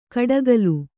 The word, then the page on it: ಕಡಕಲು: kaḍakalu